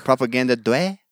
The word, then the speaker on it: Propaganda Due: Propaganda due, or due, due. propaganda due, Italian pronunciation, propaganda due.